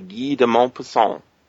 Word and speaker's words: Guy de Maupassant: All right, and we're here to talk about this story we've just heard, which is called The Horla by Guy de Maupassant. That's a good pronunciation.